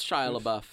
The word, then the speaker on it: Shia LaBeouf: I think it's Shia LaBeouf, yeah.